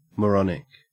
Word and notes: moronic: Australian English

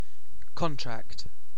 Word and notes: contract: British English

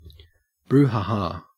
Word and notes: brouhaha: Australian English

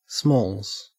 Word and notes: smalls: Australian English

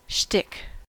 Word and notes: shtick: American English

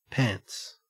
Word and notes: pants: Australian English

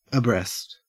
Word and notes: abreast: Australian English

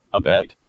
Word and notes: abet: American English

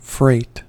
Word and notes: freight: American English

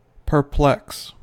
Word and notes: perplex: American English

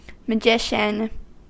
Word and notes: magician: American English

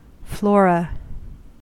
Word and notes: flora: American English

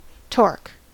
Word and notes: torque: American English

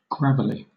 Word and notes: gravelly: British English

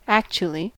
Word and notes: actually: American English

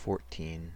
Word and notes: fourteen: American English

